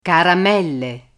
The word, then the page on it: caramelle: Italian